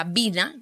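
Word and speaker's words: Wien: Entonces, bien pronunciado, ni siquiera, bueno, me enteré hace poco, se pronuncia Vina, Gabriela Vina.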